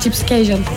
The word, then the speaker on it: Gypsy Casual: Gypsy Casual diye okunuyor bildiğim kadarıyla. – Doğru, evet, Gypsy Casual.